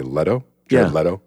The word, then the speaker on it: Leto: I like Lato's Joker more. Lego Joker. That's what you like. Leto.